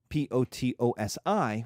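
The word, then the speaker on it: Potosí: We call it Potosi, Potosi, with an accent on the I, but you could call it Silver Mountain.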